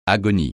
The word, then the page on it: agonie: French